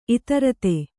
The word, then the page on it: ಇದಿರೆತ್ತು: idirettu